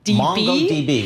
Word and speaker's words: MongoDB: Yep, of course, Meta, M-E-T-A. It's actually slightly down after a surge last week. We see Meta tapped, I don't know how to say the company name, MongoDB. – MongoDB, very good.